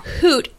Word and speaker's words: Hoot: It's Huut. Yes. Okay. It's Hoot. And I only knew that because they had a picture of an owl and it said, give a hoot, like Huut.